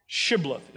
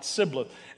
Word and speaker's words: Shibboleth: Shibboleth is the way you say it, not Sibboleth.